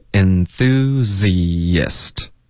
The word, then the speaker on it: enthusiast: 那当一个名词呢，这个字的发音是 enthusiast，OK，、 okay? 或者当复数的就可以用 enthusiasts，OK，、 okay? 所以复数的就加一个 s 就可以了。它的发音要注意，就是结尾的 z 跟 ist，这是两个音节，中间会添加一个很轻的 y 的音，enthusiast 有一个很轻的 y。